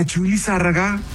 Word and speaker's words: Chuy Lizárraga: a Chuy Lizárraga y a Julio Preciado, oiga. – Correctamente. ¿Y cuál? – Lo dije bien. – Sí, lo dijo bien, lo dijo muy bien.